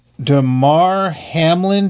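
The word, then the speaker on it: Damar Hamlin: But first of all, I want to do a quick update on DeMar Hamlin.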